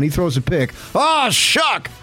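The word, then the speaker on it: Shough: It looks like Shuff or... – I know, and I screwed up. – Shuck. – It's Shuck.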